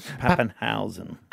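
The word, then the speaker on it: Papenhuyzen: You got to say it like that. Pappenhausen.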